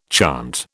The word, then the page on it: chant: British English